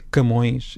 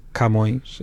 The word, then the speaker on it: Camões: Camões, também devia ser Camões, e é Camões, ou seja, a sílaba tónica é o Mões, e nós abrimos o K.